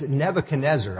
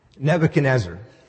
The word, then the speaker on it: Nebuchadnezzar: It's Nebuchadnezzar. Nebuchadnezzar.